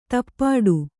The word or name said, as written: ದುಪಟಿ